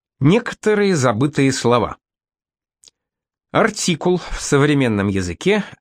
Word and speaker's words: артикул: В старину артикул с ударением на последнем слоге означал «ружейный прием».